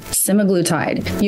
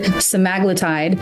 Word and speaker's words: semaglutide: So let me backtrack 1 second and say Ozempic is a name brand for a general medication, a generic medication that's called semaglutide. You may also pronounce it semaglutide. And I don't think either pronunciation is right or wrong.